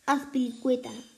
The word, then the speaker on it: Azpilicueta: Ah, Aspicue. o oh, sí. Sí, César, vamos a llamarlo César, pero es Azpilicueta.